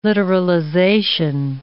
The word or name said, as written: literalization